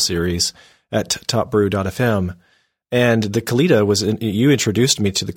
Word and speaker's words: Kalita: Kalita. It's K-A-L-I-T-A.